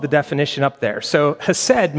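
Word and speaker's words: hesed (חסד): If you're a Jewish person or a Hebrew speaker, you would say it with a clearing of your throat, like hesed. That's actually how that first letter is pronounced, but it's hesed.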